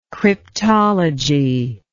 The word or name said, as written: cryptological